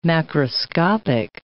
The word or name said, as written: macroscopic